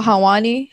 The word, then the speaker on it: Helwani: Yeah, and Ariel Helwani. How do you say his last name? – Mm-hmm. Helwani.